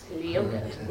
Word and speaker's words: Leota: L-O-T-T-Y. That's right. Because you have the difficult name to pronounce. – Leota. – Yes, I go. My real name is Leota, but I go by Lottie to make it easy to pronounce.